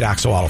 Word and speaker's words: axolotl: And the funny part is, I bet most parents learned how to pronounce axolotl because their kids corrected them. Because if you look at that spelling, there's no way you get axolotl from that.